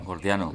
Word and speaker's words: gordiano: lleva al nudo jordiano, ¿lo he dicho bien? Jordiano. – Gordiano. – Gordiano, sí, eso.